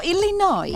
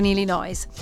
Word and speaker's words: Illinois: Chicago è in Illinois. – No, fun fact, hai un S quando termina Illinois, però non lo pronuncia.